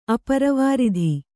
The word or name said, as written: ಅಪ್ರವೃತ್ತಿ